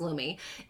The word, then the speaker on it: Lume: Lumi, we called it Lume a few weeks ago, and apologies for that. It's actually pronounced Lumi,